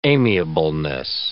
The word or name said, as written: amiableness